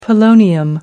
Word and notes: polonium: American English

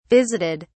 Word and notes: visited: British English

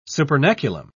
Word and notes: supernaculum: British English

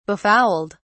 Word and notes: befouled: British English

